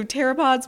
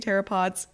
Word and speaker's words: pteropods: Now, this is spelt pteropods. So not to be confused with theropods with a TH, because those are dinosaurs. So they're very different things. But yeah, so pteropods, when you hear me say pteropods, in this podcast, I am not talking about dinosaurs.